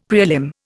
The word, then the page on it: prelim: Indian